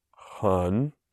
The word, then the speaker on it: 很多: Hun Dong.